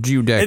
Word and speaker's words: Giudecca: I'm going with Giudecca. – It looks kind of... So. It starts kind of like Guido. So I was thinking Giudecca, or I don't know what the CC is.